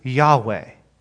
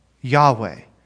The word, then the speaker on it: Yahweh: And in Hebrew, you would typically pronounce this Yahweh, Yahweh.